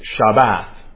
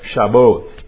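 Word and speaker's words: Shabbat (שבת): In Hebrew, you'd pronounce it something like Shabbat or Shaboth, depending on the vowel pointing.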